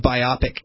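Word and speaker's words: BIPOC: So it's two virtual events were held Tuesday and were advertised as, quote, non-POC cafe, people of color, and another as it looks like it's pronounced biopic cafe.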